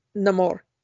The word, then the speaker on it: Namor: He always pronounces it Namor because it's sin amor without love. So he really does the amor part.